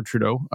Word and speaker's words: Trudeau: Uh, uh, so this is a course from a frequent Real Python contributor named Christopher Trudeau.